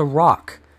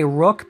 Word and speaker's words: roc: The first one is the Rock, and I'm not talking about Dwayne the Rock Johnson. This one is spelled ROC. Some say rock, some say rook, but whatever. I'm going to say rock.